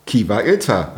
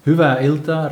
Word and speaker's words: hyvä ilta: Okay, wie spricht man es richtig aus? – Ja, das heißt, schönen Abend. – Hyvä ilta heißt.